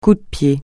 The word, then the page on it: coup de pied: French